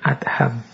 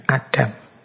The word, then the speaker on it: Adham: Membacanya memang bin Adham, bukan bin Adam.